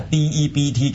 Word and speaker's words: debt: 金融即係一個字就係債啫嘛，嚇、啊、就係、是、 debt 嚇、啊、 D E B T，記住個 B 字唔發音噶嚇 debt。